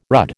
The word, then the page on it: rudd: American English